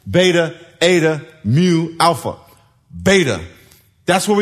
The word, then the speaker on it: eta: The second letter looks like an N, is an eta. Everyone say eta. And you pronounce it like A-Y, A. So that's bay.